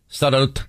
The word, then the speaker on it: Sderot: How do you say it? – Sderot.